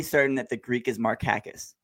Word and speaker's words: Markakis: I'm pretty certain that it's actually pronounced Nick Markakis in the Greek. And Nick, being a quiet guy, never bothered to correct anybody. So we always called him Markakis, and he was like, whatever.